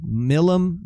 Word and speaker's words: Milam: I think that's how it's pronounced. It's M-I-L-A-M. Milam? Milam? – Milam. I go Milam. Milam. – I'll go Milam.